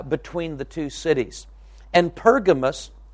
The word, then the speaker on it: Pergamos: Pergamos, or sometimes you'll hear it pronounced Pergamum, either way is correct, depending on which translation of the Bible you're reading.